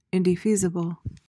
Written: indefeasible

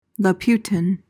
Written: Laputan

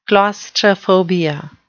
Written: claustrophobia

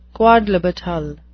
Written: quodlibetal

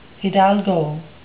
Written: hidalgo

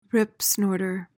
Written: ripsnorter

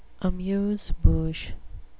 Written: amuse-bouche

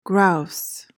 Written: grouse

